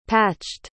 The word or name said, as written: patched